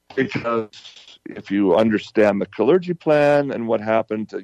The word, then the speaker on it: Kalergi: The Kalergi Plan. Spelled K-A-L-E-R-G-I, Kalergi. That's how you pronounce it.